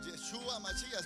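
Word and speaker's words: Yeshua Mashiach: Yeshua Machía es como se pronuncia en hebreo.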